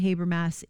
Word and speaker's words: Habermas: of the resurrection, I believe Gary Habermas is the present day authority on the historicity of the resurrection.